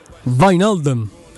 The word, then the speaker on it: Wijnaldum: sulla giusta pronuncia, che in realtà sarebbe Vinaldum Wijnaldum. Wijnaldum. È un po' cazzato però. – Come. Sì, perché eh. – è, è più Wijnaldum che Wijnaldum.